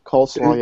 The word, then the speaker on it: Khosla: But Vinod Khosla, and I'm butchering the name. – Khosla, yes, okay.